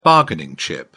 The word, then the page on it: bargaining chip: British English